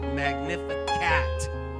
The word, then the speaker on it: Magnificat: Turn to your neighbor and say, That's the correct way to pronounce it. Magnificat.